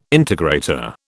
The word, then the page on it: integrator: British English